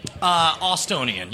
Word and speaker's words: Austonian: And also Brian Brushwood, who is a native Estonian. – Austonian, yes.